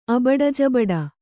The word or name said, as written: ಅಬಡಜಬಡ